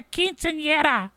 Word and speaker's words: quinceañera: And the little girl goes, it's a quinceañera. I go, oh, okay. So I got to say it like the way you say it. I have to say quinceañera.